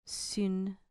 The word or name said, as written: sun